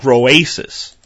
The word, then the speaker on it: Groasis: It's called Groasis. And that, that's spelled G-R-O-A-S-I-S, like Groasis, like an oasis, Groasis.